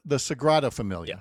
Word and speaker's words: Sagrada Familia: And then he sends along a lovely photo of the Segunda Familia. Did I say that right? I believe so. – Sure. – The Sagrada Familia.